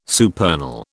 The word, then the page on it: supernal: British English